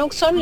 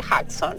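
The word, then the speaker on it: Hudson: Acá es Uxón. La gente del partido de Brazategui que vive en Uxón le dice Uxón. La gente que es de afuera le dice Jackson. Bien. Pero acá es Uxón.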